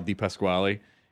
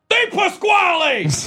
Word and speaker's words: Di Pasquale: And Eugene Levy plays this porn/film producer called Sal Di Pasquale. And he has this assistant who can't pronounce his name. She was like, "That's great, Mr. Di Pasqua." And he just shouts at her, "Di Pasquale! Moron!"